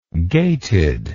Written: gated

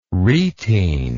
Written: retene

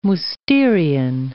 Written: Mousterian

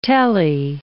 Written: telly

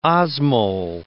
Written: osmole

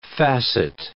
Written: facet

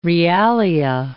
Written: realia

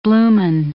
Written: blooming

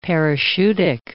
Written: parachutic

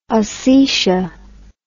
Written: osetia

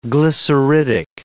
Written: glyceridic

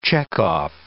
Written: checkoff

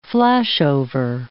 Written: flashover

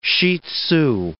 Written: shih tzu

